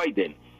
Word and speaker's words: Biden: Desde luego que está mal escrito el apellido en Biden, escribir Biden, porque es que Biden es la pronunciación, así se pronuncia Biden,